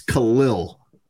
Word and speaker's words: Khalil: He's running for, 50-yard touchdowns, and they're calling him Khalil Herbert, and it's not his name. It's Khalil. – Khalil. – Lil. – Not Khalil. – Like it ends with like L-I-L-L would be how you pronounce it.